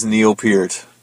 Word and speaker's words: Neil Peart: Or if today we can find a suitable robe, I will go as Neil Peart. And that is how you say his name, by the way.